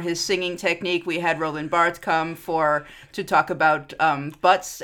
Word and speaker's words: Roland Barthes: Thank you, Roland Barthes.